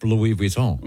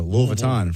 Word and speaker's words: Louis Vuitton: You don't say Louis Vuitton either, do you? – Because it's Louis Vuitton. – Louis Vuitton. – I say it like Louisville. I go, I'm getting a Louis Vuitton for my baby.